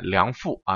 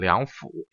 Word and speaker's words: 梁甫: 这个甫和那个杜甫的甫啊，是古代相通的字，所以在这儿不能念梁父啊，要念梁甫，要读成三声。